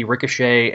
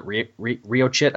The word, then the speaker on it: Ricochet: Yeah, you had Peter becoming the Hornet, Prodigy, Ricochet, and which back when I was a kid, I called it Riochit, I think is how I was pronouncing it.